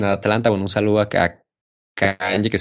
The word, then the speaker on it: Kanye: ¿Cómo se pronuncia Kanye West? Alessandro, cuéntame. – Es Kanye. Es como Kanye. Kanye West.